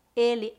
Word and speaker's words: ele: Por exemplo, ele, pronúncia fechada. Ela, pronúncia aberta.